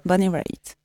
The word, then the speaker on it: Bonnie Raitt: C'est Bonnie Raitt, c'est hmm ça Bonnie Raitt.